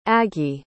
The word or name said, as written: aggy